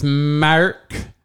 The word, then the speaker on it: Mark: Well, girl, my name is Mark. And that is spelled M-A-R-K.